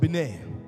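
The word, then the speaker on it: binah: See, the Hebrew word for discernment, when you are waiting in wisdom, it's called bineh. It's spelled B I Y N. Pronounce.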